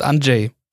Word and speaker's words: Andrzej: Wieso muss man da ein Z noch reinmachen bei dem Andrzej? – Die Coco und ich haben einen Kollegen, den schreibt man auch so und der heißt Andrzej. – Ja. – Andrzej, okay, dann heißt er Andrzej Steckala.